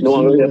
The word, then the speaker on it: Nyong'o: Lupita Nyong'o is that how you say her name? But, like yeah, Lupita Nyong'o.